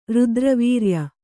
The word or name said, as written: ರುದ್ರವೀರ್ಯ